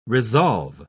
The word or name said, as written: resolve